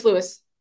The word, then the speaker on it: Louis: And it's not that it's a difficult name, but it's either Louis or Lewis Foster. – Oh, wait, no, it's Lewis. – Lewis? Okay. – No, Lewis Foster. – I'm so sorry. It's Louis.